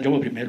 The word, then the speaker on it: Jaume I: Jaume I.